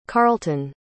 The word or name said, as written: carlton